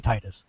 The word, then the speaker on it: Titus: Someone said, thank you. You're saying it right. Which is funny because normally I'm the one defending the Japanese pronunciation, but I think Titus is stupid. – It is. – And, you know, it's a localized American game. It should be Titus.